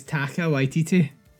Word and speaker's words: Taika Waititi: Takiki Waititi. Can you see it? – I thought it was Taika Waititi. – Oh, that was right.